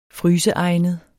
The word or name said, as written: fryseegnet